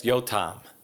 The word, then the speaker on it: Yotam: Yes, Yotam.